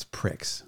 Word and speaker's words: Prix: P-R-I-C-K-S. – P-R-A-X. It's Prix.